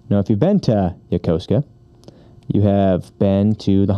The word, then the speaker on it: Yokosuka: Yokosuka? – Oh!